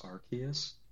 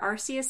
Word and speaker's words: Arceus: In the name of Arceus, I forgive you. – Oh, I believe it's pronounced Arceus. – Whatever, Arceus sounds cooler.